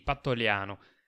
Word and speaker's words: Pantoliano: Sì, Pantoliano.